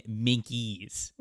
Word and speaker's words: minke: Yeah, apparently the O'Higgins Land Minx is actually the O'Higgins Land Minkies. – Minky! It's spelled M-I-N-K-E. And I was like, that's a silent E. And then I didn't think about it again. It turns out it's not.